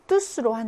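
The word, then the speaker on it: tous: 그리고 이 때오유에스도 뚜가 아니라 발음을 뚜스로 한다. 대명사일 때.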